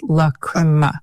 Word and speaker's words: Lachryma: How do I know it's pronounced that way? – Because you looked it up. – I looked it up because it's spelled L-A-C-H-R-Y-M-A. Please correct me if I'm wrong, and I'm sorry if I mispronounced it, but according to our sources, it's Lacrima.